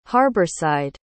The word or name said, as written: harborside